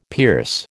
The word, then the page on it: pierce: American English